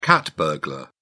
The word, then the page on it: cat burglar: British English